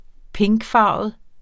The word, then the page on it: pinkfarvet: -ˌfɑˀvəð; -ˌfɑːvəð